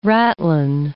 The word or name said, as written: ratline